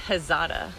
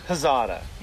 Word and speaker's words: Hazada: I've been wondering, how do you pronounce the name? Is it hey or he? – I say Hazada. – Hazada. – Yeah. – So it's more like a huh.